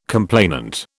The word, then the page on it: complainant: British English